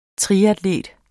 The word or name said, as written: triatlet